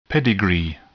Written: pedigree

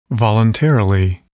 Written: voluntarily

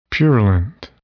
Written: purulent

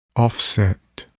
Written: offset

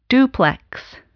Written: duplex

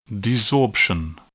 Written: desorption

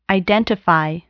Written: identify

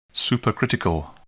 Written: supercritical

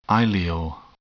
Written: ileal

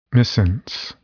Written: missense

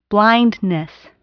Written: blindness

